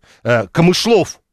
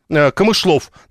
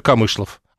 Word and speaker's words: Камышлов: Ну нет, Камышлов. Оказывается, так правильно говорить. Спасибо, Глеб Сурала, он написал нам прямо оттуда. Камышлов, надо говорить. Всю жизнь ездил и думал, что посещаю Камышлов. А он Камышлов.